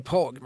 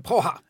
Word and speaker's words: Praha: Man ska alltså inte säga Prag, man ska säga Praha.